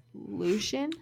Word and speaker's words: Lucien: This one I hear people pronounce wrong a lot. It's Lucien.